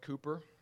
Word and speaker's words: Cowper: And it's spelled Cowper, but it's pronounced Cooper.